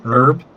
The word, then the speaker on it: herb: H-E-R-B. I'm from Midwest. I say herb. – Herb? I didn't know that. – Herb? The H is silent. Herb.